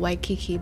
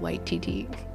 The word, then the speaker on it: Waititi: So it's not Waikiki, but Waititi. – Yeah, pretty much. And the W here is the V for us. But it's the same pronunciation, because the W here, you pronounce it V.